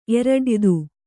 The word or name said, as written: ಎರಡಯಿದು